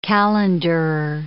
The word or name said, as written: calenderer